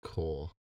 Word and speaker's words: corps: And tell you that you pronounce that word core. – Corpse. – Core. – So it's the nurse corps? – Yeah.